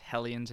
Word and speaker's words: Hellions: It probably should be pronounced Hellions and not Helions, because it's hell. There's no two E's.